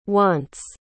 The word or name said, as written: wants